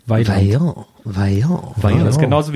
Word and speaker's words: Vaillant: und ich dachte mein Leben lang, die heißen Vaillant. – Aha. Weil man das nur gelesen hat mit V, aber das wird deutsch ausgesprochen. Weiland.